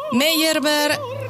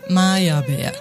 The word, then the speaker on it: Meyerbeer: Pero bueno, como anécdota, Meyerbeer lo decimos en México porque se parece mucho a la pronunciación en francés, pero si lo decimos en alemán sería Meyerbeer.